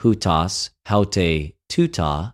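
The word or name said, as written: οὑτος αὑτη τουτο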